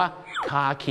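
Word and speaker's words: khaki: คาขี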